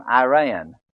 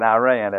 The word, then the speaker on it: Iran: They don't call it Iraq and Iran, as our news commentators do. It's Iraq and Iran. They pronounce the I as an E.